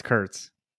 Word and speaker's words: Kurtz: Well, you keep saying Kruntz. It's Kurtz.